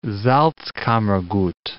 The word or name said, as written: Salzkammergut